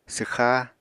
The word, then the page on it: swxá: apatani